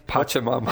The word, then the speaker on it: Pachamama: Is it Pachimami or what's the Pachimami, Pac-Man? – Pachamama.